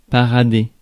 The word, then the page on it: parader: France; [pa.ʁa.de]